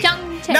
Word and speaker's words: PyeongChang: No, see, PyeongChang. No, it's like Rick Hong. It's like Rick Hong, PyeongChang.